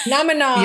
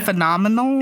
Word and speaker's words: phenomenon: First, you should say phenomenon again because you didn't say it right. – Phenomenon. – Yeah, you said phenomenal.